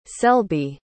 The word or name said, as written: selby